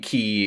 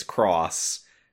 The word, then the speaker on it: χ: The fact that it's still K-H-U Kai, pronounced X, but now instead of being key, it's cross. So the acronym stays the same, but the pronunciation of the individual letters of the acronym changes. I mean, that's just chef's kiss.